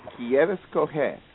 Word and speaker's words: quieres coger: Quieres coger. You said it right the first time. – Quieres coger.